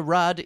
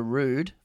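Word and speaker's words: Ruud: Casper Rudd is not Casper Rudd. It's Casper Rude, for starters.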